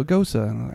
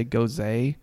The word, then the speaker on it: Gose: I, I always, you know, it's just spelled G O S E, and so I always space on how to because I had to ask the guys like I want the Chilton. How do you say that again? And he knew what I meant. Oh, Gosa. Like, okay, good. Because I always want to say Goze, like it's like a rosé.